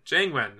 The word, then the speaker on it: Jiang Wen: Wen Cheng. – Thank you. – Sorry. Jian Wang. Jiang Wen. I got it right in front of me. I can say it right this time.